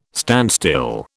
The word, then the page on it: standstill: British English